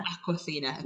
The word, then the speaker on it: Awkwafina: Is it Awkwafina, is that how I say it? – Awkwafina, okay.